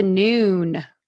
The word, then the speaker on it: Noone: Oh, it's a Noone. Okay. Noone with an E.